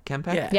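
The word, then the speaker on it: K'mpec: How you pronounce it? Kempek? – Yeah.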